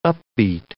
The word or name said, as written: upbeat